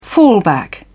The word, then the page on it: fallback: British English